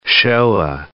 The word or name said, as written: Shoah